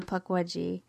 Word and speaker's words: Pukwudgie: I was forced first sorted into Pukwudgie and then Thunderbird, I think, might have been Horned Serpent. And I see myself as a Pukwudgie.